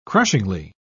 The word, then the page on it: crushingly: British English